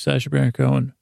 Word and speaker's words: Sacha Baron Cohen: I guess if you say it like Sacha Baron Cohen, that's how you say it, Sacha Baron Cohen. You gotta say it fast.